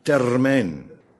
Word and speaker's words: termijn: Maar ik moet er meteen bij zeggen dat in de uitdrukking termijn de klemtoon ligt op het laatste van dit tijd die bedoeld wordt.